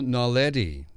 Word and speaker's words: naledi: These include Homo naledi." Now, am I saying that right, Bill? – Yes, yes.